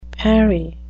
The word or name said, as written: parry